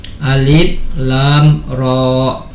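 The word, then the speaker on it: Alif Lam Ra (الر): Jadi membacanya memang harus dieja. Alif Lam Ra.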